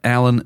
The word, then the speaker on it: Alyn: And if you've just recently started listening to our podcast, Alan is spelled A-L-Y-N.